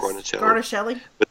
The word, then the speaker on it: Guarnaschelli: Guarnaschelli.